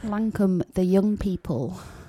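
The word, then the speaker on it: Lancôme: And I tried to say that they were pronounced Lamashan, like the Turkish pizza, but it's actually Lancum. Is that correct? – Lancum, yeah, that's the one.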